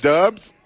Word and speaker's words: Doubs: Is that how you say it? Because it's spelled. – It's Dobbs. – Doubs. Doubs. I call them Doubs. – Doubs. – Doubs. It's Dubs.